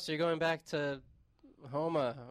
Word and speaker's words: Houma: I had to email the guy back because I wasn't sure how to pronounce this. It is Houma, Louisiana.